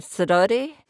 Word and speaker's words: Sidoti: Is it Sidoti? – Yes.